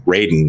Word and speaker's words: Raiden: So Raiden at the end, Raiden is how you say his name in Mortal Kombat.